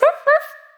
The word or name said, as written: Chien Chaud